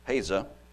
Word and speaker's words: Pesach: They call Passover Pesach, and it's spelt P-E S-A-C-H. Looks like Pasach or Pasach. But pronounced Pesa. P-E-S-A.